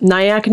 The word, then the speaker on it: Nyack: Nyack, New York.